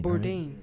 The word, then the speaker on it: Bourdain: How do you say his last name? Bourdain. – Bourdain. – Right? – Yeah.